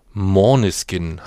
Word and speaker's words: Måneskin: Denn wir wollen heute über die Band Morniskin, haben wir uns jetzt sagen lassen, heißt es eigentlich original ausgesprochen, kommt aus dem Dänischen und steht für Mondlicht.